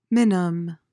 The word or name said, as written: minim